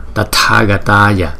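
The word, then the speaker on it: Tathāgatāya: Chữ T với chữ H chúng ta đọc là tờ hờ chứ không phải là thờ. Tờ, tờ, tờ, Tathagataya, Tathagataya, Tathagataya. Arhate, Arhate. Chữ này là A-R-H-T-E.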